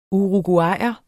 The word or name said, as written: uruguayer